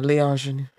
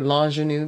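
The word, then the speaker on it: l'ingénue: Well, actually, in French, don't you say l'ingenue? Because l'ingenue. Once there's a A there, you don't pronounce the A. – The A, no. Yeah. If it's a l'ingenue. – L'ing. Because it's not. Le, and an L apostrophe.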